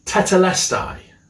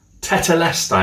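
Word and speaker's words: tetelestai (τετέλεσται): It's pronounced tetelestai. Tetelestai, I'm sure you can pronounce it better than me, but tetelestai. Try saying that. Tetelestai.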